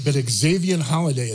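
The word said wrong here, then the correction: Valladay: is Valaday. – Sorry about that.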